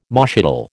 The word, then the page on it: moschatel: American English